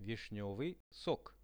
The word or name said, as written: vishnevyi sok